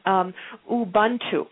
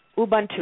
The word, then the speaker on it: Ubuntu: It's called Ubuntu. Ubuntu. It's an African philosophy. And for those of you asking how it's spelled, it's U-B-U-N-T-U.